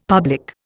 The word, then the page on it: public: Indian